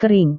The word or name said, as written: kering